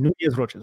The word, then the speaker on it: Nunez-Roches: So it's always Nunez-Roches. Rakim Nunez-Roches.